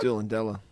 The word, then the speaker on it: Doolandella: Hey, before we get your spin it or bin it, where the bloody hell's Doola Dilla? – Next to Forest Lake. – Oh, okay. – Did I pronounce that right? – Doolandella. – Doolandella, is it? – Yeah, Doolandella, that's correct.